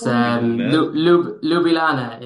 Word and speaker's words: Ljubljana: It's Ljubljana is the capital of Slovenia.